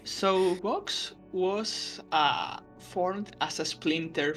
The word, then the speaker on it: Vox: Vox. – That's right. Actually, Spanish people don't distinguish between B and V, so it's you pronounce it Vox, like boxing, more like that. But yeah, it's fine both ways.